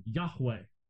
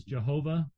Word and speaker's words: Yahweh: Now, in most English translations, they just have the word Lord, but they put it in capital forms, L-O-R-D in capital forms. But in the Hebrew language, that is the what's called the Tetragrammaton, Yahweh. We sometimes slaughter that as Jehovah in English. It could not have been pronounced that way, but be that as it may.